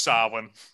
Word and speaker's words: Samhain: It's pronounced Samhain.